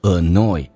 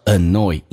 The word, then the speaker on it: annoy: 我们来看一个单词叫做 annoy，这个单词你发现很多人读成 annoy，事实上是叫做 annoy，因为 in 在前后有元音的情况下 in 是发两遍的，叫做 an，那这 a annoyed， oi 不是 oi，是 oi， annoy， very 跟 annoy 元音跟元音之间可以加个连音，加个也，叫做 very annoy， I'm very annoyed， I'm very annoyed with that scale。